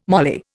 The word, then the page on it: moly: Indian